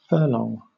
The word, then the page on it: furlong: British English